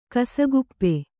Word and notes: ಕಸಕುಪ್ಪೆ: kasakuppe

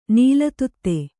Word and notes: ನೀಲತುತ್ತೆ: kannada

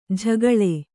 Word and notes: ಝಗಳೆ: jhagaḷe

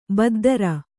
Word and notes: ಬದ್ಧರ: baddhara

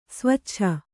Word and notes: ಸ್ವೇಚ್ಛೆ: svēcche

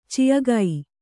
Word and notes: ಚಿಃಗೈ: Kannada

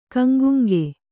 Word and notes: ಗಂಗುಂಗ: gaŋguŋga; Kannada

